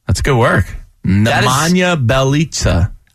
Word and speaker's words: Nemanja Bjelica: It's such a weird pronunciation because the way they hyphenetically did it was B Y E L L as the first syllable. – Nemanja Bjelica. – It's very weird to try to put that as Biel.